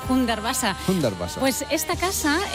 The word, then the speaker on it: Hundertwasser: No sé si lo he dicho bien, sí, que el alemán bueno, es lo mío. – Me lo han corregido antes, Hundertwasser.